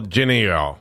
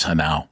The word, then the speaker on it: Genao: Well, the American version would be Angel Geneo. Angel Geneo, yeah. – Instead, it's Angel Hanau. – Okay. – That's the way that his name is pronounced, is Hanau, even though it's Genao.